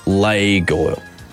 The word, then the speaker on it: Lego: Which is actually pronounced Lego. Lego. So anyone who says they're called Legos are absolutely correct. Lego is the incorrect pronunciation because it comes from Legot. L-E-G. Spelt like leg, pronounced like lay, and then got, G-O-D-T.